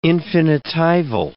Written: infinitival

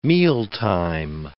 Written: mealtime

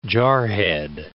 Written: jarhead

